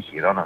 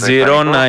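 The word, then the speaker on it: Girona: Περίπου 18-20 εκατομμύρια η Τζιρόνα ή Χιρόνα, δεν ξέρω πώς προφέρεται. Δεν το έχω δει. Τζιρόνα είναι, γιατί είναι από την Καταλωνία.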